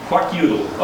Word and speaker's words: Kwakiutl: Kwakiutl. – Kwakiutl.